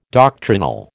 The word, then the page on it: doctrinal: American English